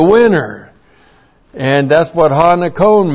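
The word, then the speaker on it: nikon (νικῶν): Ha nikon. There's the definite article. And this is N-I-K long O-N. Ha nikon.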